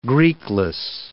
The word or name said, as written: Greekless